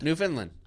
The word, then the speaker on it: Newfoundland: Is it Newfoundland or Newfoundland? – Newfoundland. – Oh, pardon me.